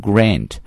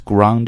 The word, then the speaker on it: grand: 美式英语呢愿意把这个 A 这个 R 这个音啊发成梅花音的 I, 所以读的时候呢会把它读成 grand champion, but British English, they're going to pronounce A sound into R sound, so it's grand champion, so either grand champion or grand champion 都是可以的，表示总冠军。